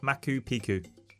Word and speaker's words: Machu Picchu: It's in Machu Picchu. – It's actually Machu Picchu, is how it's pronounced in English.